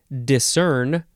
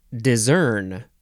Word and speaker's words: discern: Next is discern or discern. It's spelled D I S C E R N, verb from the 14th century, 1a, to detect with the eyes, as in, discerned a figure approaching through the fog.